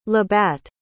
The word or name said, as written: labatt